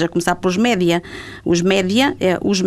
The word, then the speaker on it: média: Os média é, são os meios de comunicação e as pessoas pronunciam a inglesa não é? Eh, mídia. – Mídia, portanto, não tem nada a ver com o inglês.